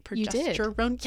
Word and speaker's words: progesterone: Progesterone, did I say that right? Progesterone, yes, promotes pregnancy.